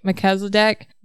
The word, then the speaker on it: Melchizedek: Melchizedek.